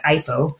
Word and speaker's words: EIFO: He is a senior vice president at Denmark's Export and Investment Fund, which is pronounced EIFO, with a special focus on wind energy and power to X.